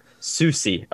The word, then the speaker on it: Soucy: But for Minnesota, they took Carson, I'm probably going to pronounce this last name wrong, Soucy. – Close. Soucy. – Soucy.